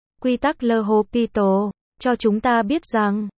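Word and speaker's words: L'Hôpital: Trong giải tích, quy tắc Le Hôpital, phát âm như lô, pi, tan,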